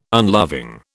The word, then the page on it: unloving: British English